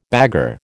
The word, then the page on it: bagarre: American English